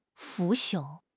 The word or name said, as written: 腐朽